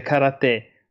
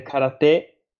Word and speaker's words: karate: Io tenderei a dire karate invece che karate perché la prima parola è kara, cioè vuoto, e te è la seconda parola che significa mano, mano vuota quindi io penso che sia più giusto karate e lo sento anche pronunciare di più in questo modo.